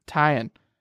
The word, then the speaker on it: Taillon: Taillon.